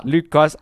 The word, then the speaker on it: Luke Goss: Luke Goss. Is that how you say it? – Oh yeah, Luke Goss.